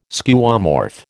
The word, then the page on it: Skeuomorph: American English